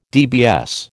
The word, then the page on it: DBS: American English